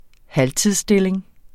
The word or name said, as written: halvtidsstilling